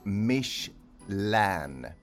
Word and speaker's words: Michelin: Namnet uttalas Michelin.